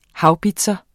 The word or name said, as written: haubits